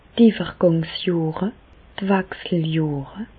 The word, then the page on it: d' Iwwergàngsjohre, d' Wechseljohre: Alsatian; Bas-Rhin; Reichshoffen